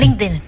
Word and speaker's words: LinkedIn: Este hombre es el duro, como decimos en Puerto Rico, y lo encuentras, como él dijo, en Instagram, Facebook, LinkedIn, ¿así se dice? – LinkedIn, ajá. – Eso, LinkedIn. – Lo dije bien.